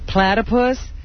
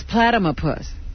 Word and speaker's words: platypus: When she found out the correct pronunciation of platypus, she said, oh, I always thought it was platymopus.